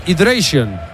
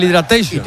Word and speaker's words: hydration: Es el hydration, no el hidratation.